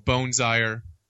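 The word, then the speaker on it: Boonzaayer: So Anyway, my last name's Boonzaier. People always ask how it's pronounced. And so there you go.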